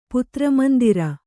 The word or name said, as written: ಪುತ್ರಮಂದಿರ